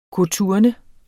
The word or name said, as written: koturne